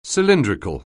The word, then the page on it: cylindrical: anglais